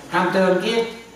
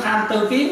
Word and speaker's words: tham tương kiến: tham tương kiến, tương kiến nha, chứ quý tử đừng nói tham tư kiến nha.